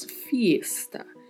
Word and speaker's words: fiesta: Mas atenção à pronúncia, porque tem uma letra I que a gente precisa pronunciar. Então, falamos fiesta. E também precisamos anasalar a letra E. Pronunciamos com som de E. Fiesta.